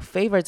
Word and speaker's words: favor: 好，favor 这个字也是 常听大家念错的。这边 a 要念成长母音 a 的声音，favor，不要念成 favor，要念成 favor。